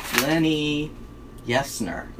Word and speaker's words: Lenni Yesner: Lenni Yesner.